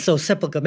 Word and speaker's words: Sepulchre: You returned day after day to the Church of the Sepulchre. Is that how you say it? The Sepulchre? – Sepulchre. Maybe the Holy Sepulchre, yes.